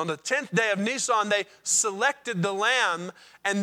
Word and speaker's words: Nisan: In the Hebrew calendar, that's the month Nisan, or Nisan, if you want to say it like the car, okay?